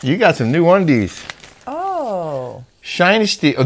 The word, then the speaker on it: Shinesty: Shinesty. Sounds like dynasty. I didn't know how you said it. And they told me it's pronounced Shinesty.